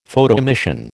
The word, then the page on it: Photoemission: American English